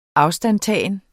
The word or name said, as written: afstandtagen